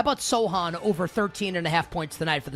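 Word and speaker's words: Sochan: How do we pronounce Sohan, right? – Yeah. – Point Sohan.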